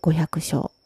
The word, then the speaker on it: 五百生: お釈迦様が残された言葉の中に、対面同席五百章という言葉があります。対面するっていう対面に、同じ、え場所にいるっていう、同席するっていう言葉、その後に、五百生きると書いて五百章って読むんですけど、